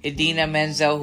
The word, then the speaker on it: Idina Menzel: And I almost mispronounced her name like John Travolta did. Idina Menzel, who's also Elsa in Frozen, and she's done Rent and so many.